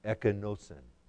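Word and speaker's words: ekenosin (ἐκένωσεν): It is from the Greek word in our passage, which is correctly pronounced, ekenosin.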